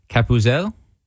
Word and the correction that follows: Gabutzel: Gabutzel. – Gabutzel.